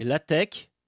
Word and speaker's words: LaTeX: En 1989, il existe déjà à l'époque une multitude de langages sémantiques pour les documents texte, comme TEC et LaTeX. Oui, on prononce pas le X final, c'est un C en fait.